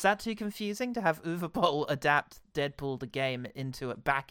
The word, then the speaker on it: Uwe Boll: What video game should Uwe Boll have done next? Or Uwe Boll, sorry, I should use the correct pronunciation that we've subsequently learned.